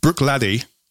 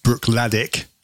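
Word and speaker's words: Bruichladdich: Brook Laddie, which is, it looks like it should be pronounced Brook Laddick, but I've been watching the film today and it's Laddie.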